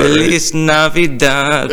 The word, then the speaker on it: Feliz Navidad: Felix? – Felix Navidad. – Yeah, it's great. – What? – Feliz Navidad? Or Lavida? – What, am I saying it wrong? – Yeah, I think so. – Yeah, I know. – At least I got the first part. – Feliz Navidad. – There you go.